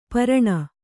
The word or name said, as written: ಪೇರಣೆ